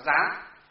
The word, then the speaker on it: gián: Những cái gián đọc là gián, đây là gián, gián có nghĩa là kẽ hở, đọc là gián có nghĩa là kẽ hở.